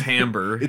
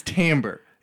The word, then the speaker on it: timbre: My music theory teacher one time was like, hey, don't say timbre because you're going to sound like an idiot. And I forgot how to say it. I thought it was timbre. No, I was like, oh yeah, idiots say timbre. – It's timbre. – It's timbre. It is timbre. Yeah, T-I-M-B-R-E spells timbre.